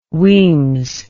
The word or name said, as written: Wemyss